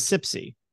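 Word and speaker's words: CPSI: Pronounced as CIPSE.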